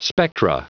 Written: spectra

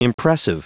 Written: impressive

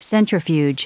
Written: centrifuge

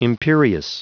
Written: imperious

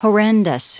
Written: horrendous

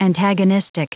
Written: antagonistic

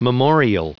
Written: memorial

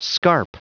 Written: scarp

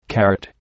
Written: carrot

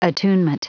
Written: attunement

